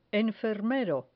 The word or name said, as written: enfermero